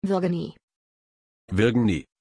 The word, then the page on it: virgenie: German